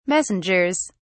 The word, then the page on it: messengers: British English